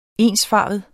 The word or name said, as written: ensfarvet